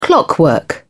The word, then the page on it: clockwork: British English